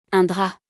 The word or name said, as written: Un drap